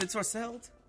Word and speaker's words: ensorcelled: It's ensorcelled? – It's ensorcelled. And although you might say that in casual speaking, I have to hold you to the correct pronunciation.